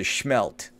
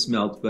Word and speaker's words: smelt: Am I saying it right or is it schmelt? – No, it is smelt.